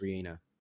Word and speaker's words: Rihanna: So I was like, let me just go look if there's a correct way to say her name. And it's Re-Ana.